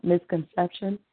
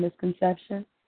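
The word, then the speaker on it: MzConception: As well, Donna, you can find me on Facebook, Misconception, MZ, CONCEPTION, Misconception, and then Connie Jones. Come up. – Spell it again so I can get it right. – It's like the word misconception, but instead of MIS, it's MZ.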